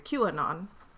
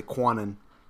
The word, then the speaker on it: QAnon: I always mispronounce it. I always say Quanon. – Yeah. Yeah. And the idea that some random guy nobody's ever known or heard of, like, no, it's a Q. He's anonymous.